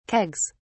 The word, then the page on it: kegs: British English